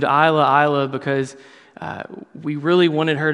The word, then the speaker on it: Isla: it's Isla, the S is silent, but there's no indication.